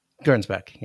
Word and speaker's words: Gernsback: Gernsback. How did you pronounce it? – Gernsback, yeah. – Gernsback, yeah. Okay. That's probably right.